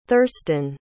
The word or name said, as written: thurston